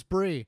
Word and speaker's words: Bri: It is Bri? It is pronounced Bree. It's the next line down. I wanted to follow up to let you know that Chris was right on how you pronounce my name. It is Bree. But Andy isn't the first person to pronounce it like Bri.